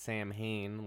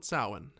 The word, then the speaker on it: Samhain: Which isn't pronounced Samhain like it's spelled. – Yeah, they get that right in this one. They call it Samhain in this one.